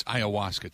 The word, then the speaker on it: ayahuasca: The ayahuasca. Ayahuasca. Remember that? Aaron Rodgers. Is that how we pronounce it, Ben? Is ayahuasca? – Yeah, I think so.